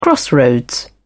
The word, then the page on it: crossroads: British English